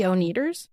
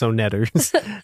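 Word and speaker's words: Oneders: I think you mispronounced that name. – Which? – The Oneaters. – Right. Hey, no, it's Onetters.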